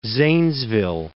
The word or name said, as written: Zanesville